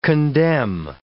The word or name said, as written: condemn